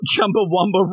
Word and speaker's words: Chumbawamba: You want Chumba Rumba Rolled? I was thinking more like... – Say it right. Do it again. Try again. – Chumba Wumba Rolled.